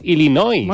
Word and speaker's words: Illinois: Se pronuncia Illinois. – Sí, perdón, sí. – Illinois. – Illinois. Pues yo digo Illinois, si no entendemos todos. Porque como diga Illinois, nadie lo entiende.